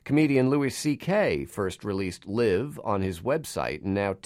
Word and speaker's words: Live: Looks like Live, but it's pronounced Live